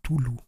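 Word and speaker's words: Cthulhu: Bueno, eh, se supone que no es algo que se pueda pronunciar, pero y la primera letra suena como más gutural, así que sería como Tulu.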